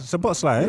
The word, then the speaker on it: Szoboszlai: I can't, Szoboszlai. – Yeah, he said it right, yeah.